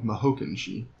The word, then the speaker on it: Mahokenshi: yeah, it's spelled M O H O K E N S H I. So Mahokenshi.